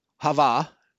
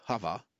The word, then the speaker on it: Hava: Eve, Hava, is the actual Hebrew pronunciation, Hava, life giver.